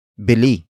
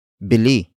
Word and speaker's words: bili: Bilhin comes from the root word bili. Bili. Bili, which is spelled as B I L I.